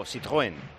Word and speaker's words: Citroën: Es que hay que pronunciar francés como Citroën.